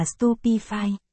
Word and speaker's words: Stupefy: Hermione, nghe này, anh dạy em một loại bùa có thể làm choáng mục tiêu, câu thần chú là Stupefy, phát âm là Stupefy.